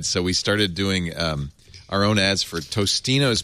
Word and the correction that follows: Totino's: pizza rolls. And then we found out that it was Totino's. We were saying it wrong for a long time.